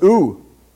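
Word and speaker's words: ooh (οὐ): The other word that he uses with this is ou. Pronounced ooh.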